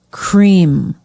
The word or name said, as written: Cream